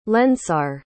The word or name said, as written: lensar